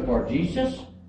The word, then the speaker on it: Bar-Jesus: He had a first name was Bar-Jesus. Am I saying that right, Sandy? Mm-hmm.